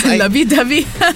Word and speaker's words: Lovey-Dovey: Lovey-dovey. – or however they pronounce it in this song. – Like, yes. I think you got it right. The correct Korean pronunciation.